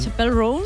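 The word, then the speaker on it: Chappell Roan: Se escribe Chapelle Roan. – Chapel Roan.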